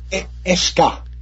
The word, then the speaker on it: Escher: And this next project we're going to talk about, and hopefully I'm saying it right, the Escher Trilogy, is that how it's pronounced? – Escher. – Oh, Escher Trilogy? – Yeah, the CNH, like it'd be a school. Escher Trilogy. – You got it.